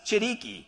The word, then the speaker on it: Chiriqui: It's Chiriqui, okay? Like chiclets? Chiriqui?